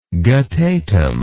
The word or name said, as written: guttatim